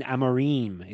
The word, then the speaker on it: Amorim: And I was saying her name wrong. It was Jacqueline Amarim. It kind of rhymes.